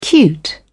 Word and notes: cute: British English

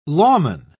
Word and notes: lawman: British English

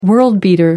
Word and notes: world beater: American English

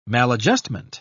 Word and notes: maladjustment: British English

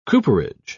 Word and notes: cooperage: British English

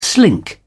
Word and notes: slink: British English

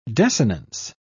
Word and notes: desinence: British English

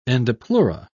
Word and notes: endopleura: British English

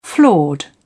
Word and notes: flawed: British English

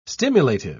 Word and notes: stimulative: British English

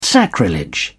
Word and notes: sacrilege: British English

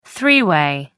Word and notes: three-way: American English

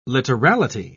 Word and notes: literality: British English

